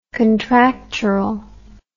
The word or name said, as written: contractural